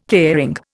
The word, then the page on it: caring: Indian